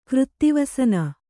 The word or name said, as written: ಕೃತ್ತಿವಸನ